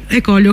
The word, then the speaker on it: Ecolio: Ecolio, come si pronuncia? – Ecolio.